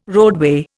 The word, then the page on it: roadway: Indian